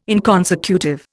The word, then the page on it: inconsecutive: Indian